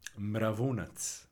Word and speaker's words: Mravunac: Mravunac.